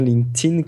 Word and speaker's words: LinkedIn: Euh, la vraie prononciation officielle qu'on est censé faire, c'est LinkedIn. Voilà, ça c'est pour tous les puristes qui auront écouté tout le début du dossier et qui vont nous taper dessus, moi le premier, pour avoir prononcé LinkedIn, prononcez bien LinkedIn comme s'il y avait un T.